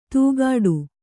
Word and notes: ತೂಗಾಡು: tūgāḍu

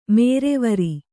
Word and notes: ಮೇರೆವರಿ: Kannada

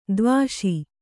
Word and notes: ಧೀವಶ: dhī vaśa

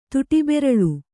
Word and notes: ತುಟಿಬೆರಳು: kannada

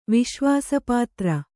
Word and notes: ವಿಶ್ವಾಸಪಾತ್ರ: viśvāpātra

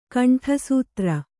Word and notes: ಕಂಠಸೂತ್ರ: kaṇṭhasūtra

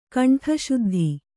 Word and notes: ಕುಂಟುಸುದ್ದಿ: kuṇṭusuddi

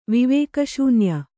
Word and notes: ವಿವೇಕಶೂನ್ಯೆ: Kannada

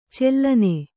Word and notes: ಚೆಲ್ಲನೆ: cellane

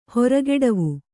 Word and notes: ಹೋರುಗೆಡವು: kannada

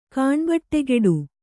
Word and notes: ಕಾಣ್ಬಟ್ಟೆಗೆಡು: kāṇbaṭṭegeḍu; Verb

